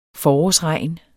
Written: forårsregn

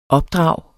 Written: opdrag

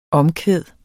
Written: omkvæd

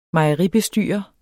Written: mejeribestyrer

